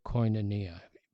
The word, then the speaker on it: koinonia: The Greek word is the word koinonia, and it's spelled Sandra, K-O-I-N-O-N-I-A, koinonia.